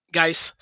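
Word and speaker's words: Guice: He's a running back with Darius Goose, I believe, backing him up. Geis, sorry, butchered his name.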